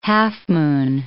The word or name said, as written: half-moon